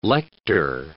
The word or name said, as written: lector